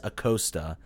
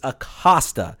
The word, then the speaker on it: Acosta: I when he pronounces Luciano Acosta as Acosta, like, it's like nails on a friggin' chalkboard. Find me the friggin' A in that name. It's Acosta. It's always been Acosta.